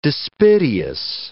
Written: dispiteous

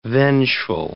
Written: vengeful

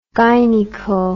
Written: gynaeco

